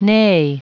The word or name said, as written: neigh